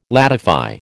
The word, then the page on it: ladify: American English